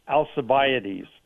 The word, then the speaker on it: Alcibiades: I probably can't say the name, but Alcibiades, is that right? – I think it's pronounced Alcibiades.